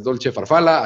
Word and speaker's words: Dolce Farfalla: Búsquenla en Instagram, Dolce Farfalla, así se escribe, pero es Dolce Farfalla.